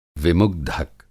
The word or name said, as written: विमुग्धक